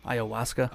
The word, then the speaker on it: ayahuasca: Now, what really gets him makes him crazy is his, how do you pronounce it? – Ayahuasca. Yeah.